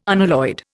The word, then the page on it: unalloyed: Indian